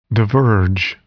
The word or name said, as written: diverge